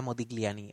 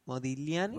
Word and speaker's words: Modigliani: pues este era Modigliani, el que se refiere. Y este hombre era... – ¿Sabes qué se pronuncia Modigliani? – ¿Modigliani? – Modigliani. – ¿Modigliani? – Sí, es que Modigliani. Estoy aprendiendo italiano ahora.